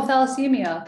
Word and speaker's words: thalassemia: And I've also I've also had clients um, at work that have talamacina. Tal- am I saying that right? Talamacina. Uh, um, talamacina. Am I? – Oh, thalassemia. Thalassemia. Yeah, thalassemia yes.